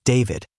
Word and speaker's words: D4VD: Authorities had not publicly named D4VD, pronounced David, as a suspect in the case.